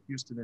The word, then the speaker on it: Houston: Oh, if I answer this correctly, hey, Basil, it is south but of Houston, but they don't say it Houston in New York City. It's pronounced Houston.